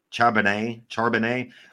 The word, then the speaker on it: Charbonnet: Clabonet? – Shabbat. – It's exactly what you just said, Rich, but it's a sh sound. Charbonnet.